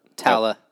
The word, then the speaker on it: Tala: Tala.